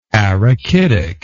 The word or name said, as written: arachidic